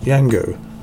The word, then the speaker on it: Django: Just released on DVD in the UK, I would say, is the Quentin Tarantino movie called Django. The D is silent.